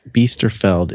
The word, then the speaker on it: Beasterfeld: I am on Twitter at Mike Biesterfeld. It's spelled just like it sounds.